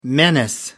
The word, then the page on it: menace: American English